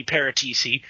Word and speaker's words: Paratici: It looks like Juventus sporting director Fabio Paratici, not sure how you pronounce that. I believe one C, so it would be Paratici, attended the Community Shield today to talk to Man City execs about the Cancelo-Danilo exchange.